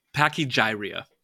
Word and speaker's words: pachygyria: And is that when you got the, how do I pronounce it again? – Pachygyria. – Pachygyria. I can never remember which emphasis the syllable goes on. – Yeah. – The other way around.